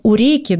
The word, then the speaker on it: Уреки: Открыла бесплатный реабилитационный центр для особых детей в Грузии, в месте под названием Уреки. Да, правильно я ударение поставила? – Правильно. Единственный момент, мы пока только готовимся к открытию и очень надеемся принять первых пациентов вот после коронавирусной истории.